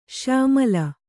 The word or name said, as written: ಶ್ಯಾಮಳ